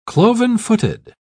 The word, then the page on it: cloven-footed: British English